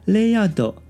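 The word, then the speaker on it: RAYARD: 它是位于这个楼层的二三楼哦，名称叫做雷亚斗，当然是日文发音哦，雷亚斗米亚西达帕克哦。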